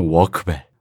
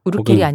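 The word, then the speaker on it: Urquell: 우리가 영어식으로 발음했다 이겁니다. 근데 이제 수출용의 독일어 이름을 붙인 거기 때문에 필스너 워크벨. – 우르켈이 아니라 워크벨. – 혹은 필스너 워크벨. 독일어에서 Q 뒤에 U는 비읍 발음에 가깝다고 합니다.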